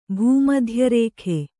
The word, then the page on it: ಭೂಮಧ್ಯರೇಖೆ: bhū madhya rēkhe